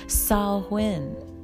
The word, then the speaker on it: Samhain: So this is also happening on the holiday of, and I'm going to butcher this, but it's Samhain, pronounced Samhain, right? If you read it, it's Samhain, but Samhain. This is a Celtic festival.